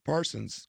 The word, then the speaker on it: Parsons: I'm going to go with Micah Pearson. – Parsons. Second time you've done that. – Did I say that the first episode? It's because it's spelt on my notes. – Pearson.